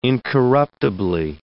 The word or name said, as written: incorruptibly